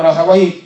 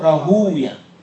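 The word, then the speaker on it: راهويه: طبعا راهوي تنطق راهوي وتنطق راهوية، أهل اللغة يقولون راهوي، علماء الحديث يقولون راهوية، واضح؟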